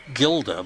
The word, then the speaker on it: Gilda: Gilda, whose name was spelled Gilda but with a soft G,